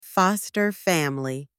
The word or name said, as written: foster family